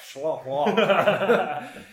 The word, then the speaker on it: Schlafly: If you're curious, it's spelled S-C-H-L. A-F-L-Y. – Schlafly. – It's easier to say if you just kind of like slur it.